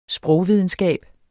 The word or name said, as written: Sprogvidenskab